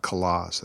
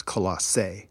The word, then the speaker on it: Colossae: It's not Colossus, I think it's Colossae. It's a proper way to say it in the Greek.